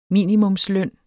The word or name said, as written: minimumsløn